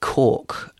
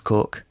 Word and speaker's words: caulk: Yeah, on Wordle, there have been some slightly odd ones like cork, as in decorator's cork, which threw a lot of people because it's not spelled like that at all. – That's fine. That's an everyday word. C-A-U-L-K.